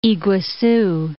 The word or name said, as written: Iguazu